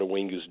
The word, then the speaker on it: Uwingu: Owingu's doing.